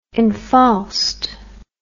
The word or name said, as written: infaust